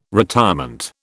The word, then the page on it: retirement: British English